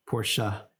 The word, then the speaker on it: Porsche: Porsche.